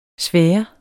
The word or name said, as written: sfære